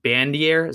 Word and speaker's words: Bandier: Is it Bandier? Is that how you say it? – Yep.